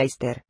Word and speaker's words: Easter: Y a este propósito es interesante observar que el nombre Easter, que es el nombre inglés de la Pascua de Resurrección, y que se pronuncia Easter, se remonta a la Virgen Madre del Muerto Tammuz Ishtar 12.